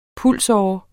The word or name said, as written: pulsåre